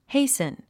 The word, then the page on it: hasten: héisn; ヘイスン